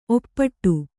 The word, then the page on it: ಒಪ್ಪಿಡು: oppiḍu